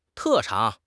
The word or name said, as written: 特长